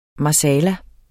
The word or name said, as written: marsala